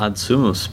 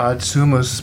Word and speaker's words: Ad Sumus: The Ad Sumus prayer. – Ad Sumus prayer.